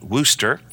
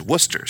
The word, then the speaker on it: Worcester: We were able to minister last week to a church in Worcester, Ohio. And if you're not from Worcester, you call it Wooster. I was calling it Wooster until they said it's not Wooster. It's not like a rooster, it's Worcester.